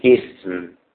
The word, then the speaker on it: اسم: وهمزته همزة وقف، الاسم الاسم، ما هو شيء الاسم الاسم، انما همزه الوصل ينطق بها اذا جاء في اول الكلام، اقول اسم اسم، انما عند الوصل همزه الوصل لا ينطق بها، همزه الوصل لا ينطق بها الا اذا بدانا بها.